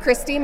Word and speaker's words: Kristy: Christy Myhill, K R I S T Y, Myhill, M I H I L L.